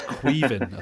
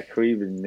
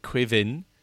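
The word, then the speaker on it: Caoimhín: It's Queven, I think, isn't it? – Yeah, Queven, yeah. – Queven. And then when I looked at it today, because I was doing it for a video, there was all sorts of argument about where you come from. Like, oh, if you come from Munster... You pronounce it Quivin. And then there's also Keevan without the Quee at the beginning.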